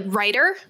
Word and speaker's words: Ryder: Well, because it's like right or Ryder, but it's like you have to say it like it's the profession, like writer. It's like writer, not writer.